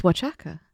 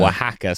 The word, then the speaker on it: Oaxaca: There's an X in there, which I think is pronounced ch. – So you think it's Oaxaca? – No, in Mexican Spanish, because it's like... – Is it different in Mexican Spanish? – It's like... what do they call it? Mexico. – Mexico. – How do they pronounce X? – Oh, right.